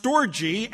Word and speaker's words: storge: I believe it's pronounced storge, S-T-O-R-G-E, and that's that love between brothers and sisters and cousins, that type of family.